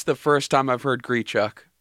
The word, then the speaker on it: Grichuk: It is Grichuk.